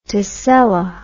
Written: tessella

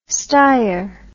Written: steyr

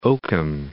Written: Oakham

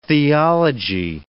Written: theology

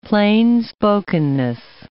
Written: plainspokenness

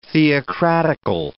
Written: theocratical

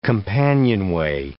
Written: companionway

